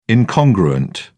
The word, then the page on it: incongruent: British English